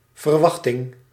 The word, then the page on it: verwachting: /vɛrˈʋɑɣtɪŋ/